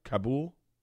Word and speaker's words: Kabul: So when we flew home from Denver, the Denver airport looked like Kabul. I mean, it was... Did I say that right? Kabul? – Yeah, I think Kabul is right.